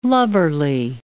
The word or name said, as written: lubberly